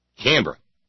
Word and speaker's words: Canberra: I will never say all the syllables in Canberra again. Canberra.